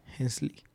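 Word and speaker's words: Hensleigh: H-E-N-S-L-E-I-G-H. E e Hensley. – Hensley.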